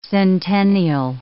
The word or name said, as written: centennial